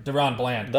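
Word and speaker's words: DaRon Bland: It's like, I understand Deron Bland or whatever. I don't know how to say it. Deron Bland. – That was it.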